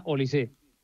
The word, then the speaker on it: Olise: Lleva acento, pero se pronuncia Olise.